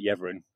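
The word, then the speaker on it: Yeavering: In fact, thinking about from Yeverin, I don't know, is it pronounced Yeverin, the place now? – I pronounce it Yeverin.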